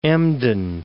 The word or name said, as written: Emden